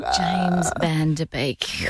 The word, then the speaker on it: James Van Der Beek: Wow, right person, and then pronounce the name wrong. – James Van Der Beek.